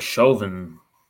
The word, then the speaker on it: Chauvin: The main guy, good old, how you say his name, sir? – Uh, Derek Chauvin. – Good old Derek Chauvin.